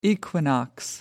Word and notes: equinox: American English

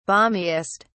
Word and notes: balmiest: British English

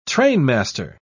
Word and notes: trainmaster: British English

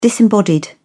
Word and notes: disembodied: British English

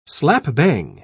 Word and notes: slap-bang: British English

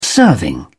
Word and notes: serving: British English

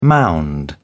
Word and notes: mound: British English

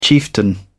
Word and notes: chieftain: British English

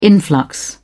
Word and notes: influx: British English

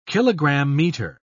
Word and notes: kilogram-meter: British English

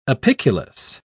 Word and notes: apiculus: British English

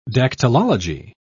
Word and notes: dactylology: British English

